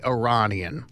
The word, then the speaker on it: Iranian: But I believe that the correct pronunciation is probably Iranian. – Okay. – Iranian. You say Tehran, Iran. – Yeah. – You say Iran.